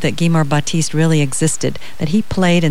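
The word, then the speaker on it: Gemar Batiste: Could you pronounce the name of the main character in that book? – His name is Guimer Batiste. – Guimer? – G-E-M-A-R. Guimer Batiste.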